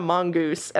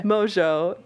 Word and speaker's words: Mongeau: Because it's because some people call her Tana Mongeau. – And yes, yeah, because her name, her last name is spelled in a way that's not phonetically how it's pronounced, yeah. – Mojo, thank you.